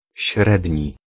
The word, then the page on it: średni: /ˈɕrɛdɲi/